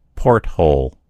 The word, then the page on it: porthole: American English